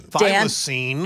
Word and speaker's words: thylacine: Thylacine.